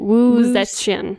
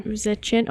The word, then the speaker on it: Wu Zetian: Wu Zetian. – Wu Zetian.